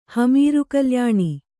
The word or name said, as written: ಹಮೀರುಕಲ್ಯಾಣಿ